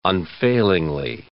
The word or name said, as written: unfailingly